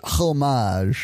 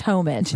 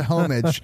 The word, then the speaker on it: homage: It definitely is an homage. – And I think it's pronounced homage. – My bad. It's a homage.